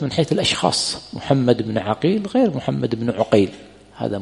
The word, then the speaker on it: عقيل: النطق، كما قلنا عقيل وعقيل بفتح العين وبضم ماذا؟ العين.